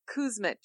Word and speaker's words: Kuzmic: Yeah, it's actually Kuzmic. – Mitch. – Oh, I know it doesn't look like that, right.